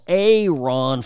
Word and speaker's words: Aaron: All right, by the way, here in Nevada, speaking of social media, our own Attorney General, A. Ron Ford, yes, it's spelled Aaron, but he pronounces it A. Ron.